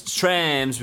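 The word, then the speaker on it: TRAAMS: It's Trams because it's T-R-A-A-M-S. – So you have to say it like that.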